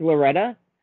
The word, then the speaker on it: Loretta: My next one is Mark Lariota. Lorettta. Maybe I'm saying that wrong. – Mark Loretta? – Yeah, correct. Yeah.